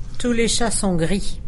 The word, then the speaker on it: Tous les chats sont gris: Then on March 21st, help me with this pronunciation. – Tous les chats sont gris. – Tous les chants sont gris.